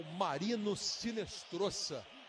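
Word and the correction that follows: Marino Hinestroza: Se escreve com H, mas a pronúncia em espanhol é com R, Marino Rinestrosa.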